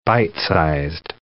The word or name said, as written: bite-sized